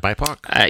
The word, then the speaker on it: BIPOC: BIPOC? – Yeah, that's how I've been saying it.